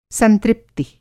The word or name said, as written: संतृप्ति